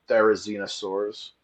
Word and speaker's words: Therizinosaurus: Or Therizinosaurus. – It literally, I call it the There Is No Saurus because that's what it looks like.